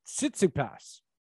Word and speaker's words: Tsitsipas: Yeah, my other pronunciation was Tsitsipas.